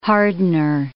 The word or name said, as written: hardener